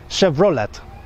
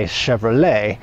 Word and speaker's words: Chevrolet: They don't say Chevrolet. I don't think they know to put that French twist on the suffix of that word. They pronounce it Chevrolet. So if you are pronouncing it, Polish people, Chevrolet, you gotta say Chevrolet if you wanna say it correctly. So Chevrolet, no, Chevrolet.